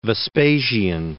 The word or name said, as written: Vespasian